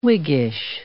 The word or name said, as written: Whiggish